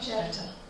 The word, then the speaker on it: Jephthah: Jephthah.